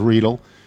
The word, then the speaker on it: Riedel: Riedel in Danville, Arkansas, I can confirm the pronunciation of Riedel. It is like needle, but with an R.